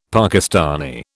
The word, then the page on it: Pakistani: British English